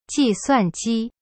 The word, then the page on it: 计算机: ji-suan-ji